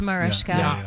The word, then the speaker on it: Mariska: It's Mariska.